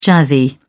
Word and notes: jazzy: British English

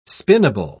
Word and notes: spinnable: British English

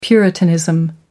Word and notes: puritanism: American English

